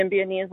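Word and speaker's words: Symbionese: And when we say SLA, that's the Sibionese. Am I saying that right? – Yeah, the Sibionese Liberation Army.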